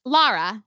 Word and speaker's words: Lara: Lara. – How you spelling that, sis? – L-A-R-A.